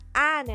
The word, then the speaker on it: Anne: And the second mistake is, I was pronouncing Annie. That's not Annie, that's spells like Anne and Frank.